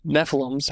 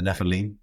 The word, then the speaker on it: Nephilim: What were the Nephilims? How do you say that? – The Nephilim? – Nephilim, okay.